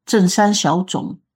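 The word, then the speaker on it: Zheng San Xiao Zhong (正山小种): So Zheng San Xiao Zhong, that is in Chinese pronunciation, but I think in English probably pronounces Lam San Xiu Chong.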